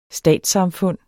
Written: STATSSAMFUNDENES

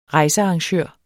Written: rejsearrangør

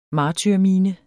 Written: martyrmine

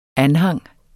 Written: anhang

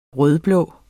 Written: rød-blå

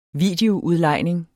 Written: videoudlejning

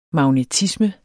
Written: magnetisme

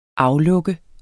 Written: aflukke

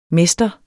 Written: mester

